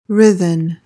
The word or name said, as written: writhen